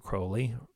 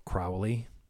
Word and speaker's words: Crowley: Moonchild, of course, was the novel written by Aleister Crowley, or Crowley. I think the proper pronunciation is actually Crowley.